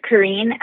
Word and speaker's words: Corinne: My name is Corinne Eldor. I get some confusion about how to pronounce my name sometimes, so someone told me just tell people that it rhymes with serene.